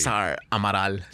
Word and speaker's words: Cesar Amaral: Cesar Amaral. – Cesar Amaral. – Yeah, it's from the Cesare. It's from the Italian Cesare, which has now been brought to the United States as Caesar.